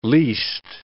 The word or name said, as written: least